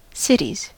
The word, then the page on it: cities: American English